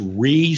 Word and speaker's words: Rhea: I believe her name is pronounced. It's not Rhea. It's Ree Sehorn.